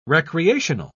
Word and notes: recreational: British English

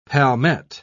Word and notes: palmette: British English

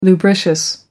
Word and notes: lubricious: American English

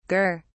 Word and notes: gur: British English

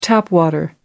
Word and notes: tap water: American English

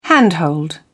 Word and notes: handhold: British English

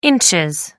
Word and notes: ins: American English

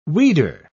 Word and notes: weeder: British English

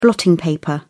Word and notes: blotting paper: British English

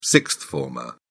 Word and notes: sixth-former: British English

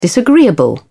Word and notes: disagreeable: British English

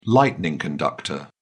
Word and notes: lightning conductor: British English

